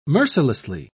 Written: mercilessly

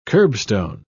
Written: curbstone

